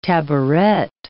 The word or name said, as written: tabouret